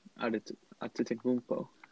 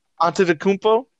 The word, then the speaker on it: Antetokounmpo: Antetokounmpo. – Antetokounmpo. – That bad. – Antetokounmpo. – Okay, not that bad. – Giannis Antetokounmpo.